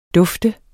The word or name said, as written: DUFTET